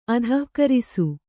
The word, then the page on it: ಅಧಃಕರಿಸು: kannada; adhahkarisu